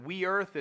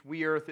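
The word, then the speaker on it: weorþ: One is, I think you pronounce it we earth. It's something like W-E-O-R-T-H, we earth.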